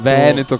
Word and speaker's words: Veneto: C'è, cioè, il fatto che tu lo pronunci Veneto, Veneto con la E aperta.